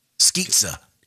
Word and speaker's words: Skizza: I think it's pronounced Skizza. – Skizza, like pizza.